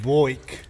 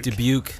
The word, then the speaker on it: Dubuque: Boik. – Dubuque. – All right. I never knew how to say it.